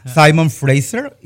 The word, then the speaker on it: Simon Fraser: Simon Fraser. ¿Lo dije bien? – Simon Fraser.